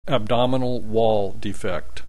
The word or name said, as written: abdominal wall defect